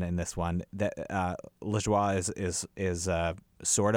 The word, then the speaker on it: Lajoie: Most of them are written by a guy named um, Jon Lajoie or La, La Joie because because it's, it's, spelled like the French for. – He's for Canadian. Joy. He's from Quebec, so I bet oh, it is right. La Joie.